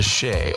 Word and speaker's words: Che: Of course, Shea is a bar and a grill here in Abuja. It is spelled C-H-E, so make sure you go there for good vibes only.